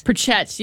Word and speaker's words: Pritchett: That is Aaron Pritchett, or Prickett, I think it was. I feel like Pritchett? – Pritchett, yes. I feel like I said it wrong many times,